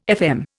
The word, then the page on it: FM: Indian